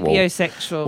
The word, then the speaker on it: sapiosexual: Came out as sapiosexual. Is that how you say it? Sapiosexual. – Sapiosexual.